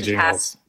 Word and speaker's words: Gingles: I mean, maybe like a hard G. I don't know. Jingles versus Thornburg. – It's definitely Jingles.